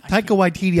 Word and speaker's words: Taika Waititi: Taika Waititi, I think.